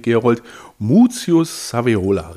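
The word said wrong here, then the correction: Scaevola: richtig? Sevola. Sevola.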